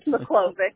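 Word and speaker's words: Miklovic: It's McClovic.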